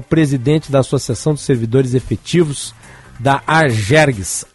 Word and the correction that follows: Acergs: Acergs, pronunciei errado, li errada, né, a pronúncia, né, da sigla.